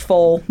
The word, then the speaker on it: Pfoll: I believe it's pronounced Foll, but it looks like Pfoll.